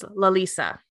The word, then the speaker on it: Larissa: And her real name is spelled like in, not like Roman, but you know, how we write, you know, as Larissa, but it's pronounced and written in like Hangul as Lalisa, like Lisa from Blackpink.